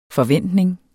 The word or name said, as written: forventning